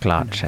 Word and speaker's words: Glace: So yeah, I think it's pronounced Glace. I'm pretty sure.